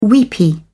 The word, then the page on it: weepy: British English